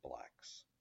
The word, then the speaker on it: BLKS: BLKS is the title, but it's pronounced Blacks.